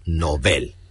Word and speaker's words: Nobel: Ambas palabras son agudas, por lo tanto, su pronunciación adecuada es Nobel.